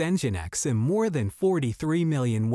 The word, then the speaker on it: Nginx: Nginx, pronounced Nginx, has developed as a popular web server solution for many developers and admins.